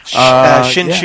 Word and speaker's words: Hsinchu: My sister Cindy was born near, I believe it's pronounced Hsinchu, but she was born in Taiwan. – Hsinchu, yeah. Spelled H-S-I-N-H? – H-S-I-N-C-H-U.